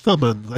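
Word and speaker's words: Thummim: And some people think that might have gone back to the Ermin and Thurman. And I was going to bring that up. – I think that was pronounced Thumman. I said Thurman. – Yeah. – I pronounced that wrong.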